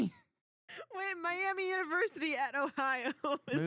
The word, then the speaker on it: Miami: It's Miami.